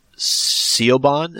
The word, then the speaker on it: Siobhan: Siobhan, is that how you pronounce that? Do you know? – That sounds about right.